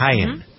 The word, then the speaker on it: Haiyan: Is it Haiyan? Is that how you pronounce it? – Haiyan, I believe. – Haiyan, right.